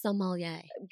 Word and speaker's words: sommelier: What are these senses that you're talking about that the sommeliers have? Also, before you answer that, tell us the proper pronunciation of, am I saying it right? Sommelier? – You're saying it beautifully. Yeah, sommelier.